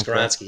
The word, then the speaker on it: Skoronski: But yeah, I mean, Skoronski, am I pronouncing that one? – Skoronski, yeah.